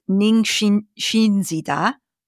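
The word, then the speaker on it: Ningishzida: So I think it's Ningxinzida. – That's perfect.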